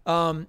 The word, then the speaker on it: Coipel: I'm butchering that name. Olivier Coipel. – Oh, it's Coipel.